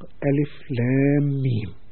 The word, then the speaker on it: الم: بدأت السورة كسورة البقرة وكغيرها كبعض السور بهذه الحروف المفردة، هذه الحروف الثلاثة الألف واللام والميم، وتنطق ألف لام ميم، ومعناها يشد انتباه المستمع والقارئ إلى التركيز والإنصات لما يتلى عليه.